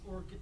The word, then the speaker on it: Orkut: Is this Orchid like a flower, or how do you spell that? – O-R-K-U-T. Orchid.